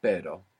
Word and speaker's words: pero: So initial R or two R's together inside the word is gonna pronounce this double R. So the word for but in Spanish is pero. – Pero. – P-E-R-O. And the word for a dog is perro. P-E-R-R-O.